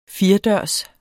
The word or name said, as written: firedørs